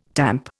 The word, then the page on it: tamp: Indian